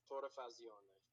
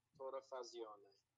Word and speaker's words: Torrefazione: It's going to be Torrefazione. – Torrefazione? – Torrefazione. – And then Facci. Italia? – Torrefazione Italia.